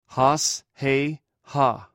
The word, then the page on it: hos: greek